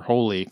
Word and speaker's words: Hole: And anytime somebody says his name, it's supposed to be Hole, I think, or Holy, close to that in the actual pronunciation in Norway.